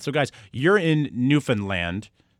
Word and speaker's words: Newfoundland: Newfoundland. Please pronounce it better for me, guys. – Newfoundland. – Okay, so it's heavy on the land part.